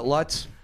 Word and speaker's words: Lutz: Bae, what rhymes with Lutz? – Clutch. – And putts.